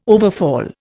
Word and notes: overfall: Indian

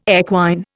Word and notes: equine: Indian

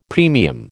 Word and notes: premium: American English

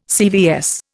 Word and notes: CVS: Indian

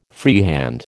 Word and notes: freehand: American English

